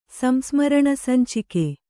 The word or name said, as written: ಸಂಸ್ಮರಣಸಂಚಿಕೆ